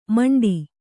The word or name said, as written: ಮಿಂಡಿ